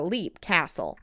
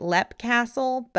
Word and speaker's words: Leap Castle: Leap Castle. I've heard it pronounced both ways. I'm going to continue calling it Lepp Castle, but I've heard some people call it Leap Castle.